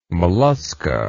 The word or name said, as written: Mollusca